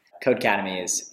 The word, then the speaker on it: Codecademy: Codecademy is the correct pronunciation.